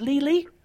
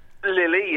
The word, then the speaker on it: Lili: Is it Lili? – Lili, yes. – Lili, exactly.